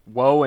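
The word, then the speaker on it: Wo: Woe and Shade. – Woe and Shade. So that's not ominous. But it's not spelled like the word woe, it's just W-O.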